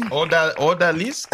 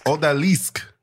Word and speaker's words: Odalisque: Odalisque. – Odalisque.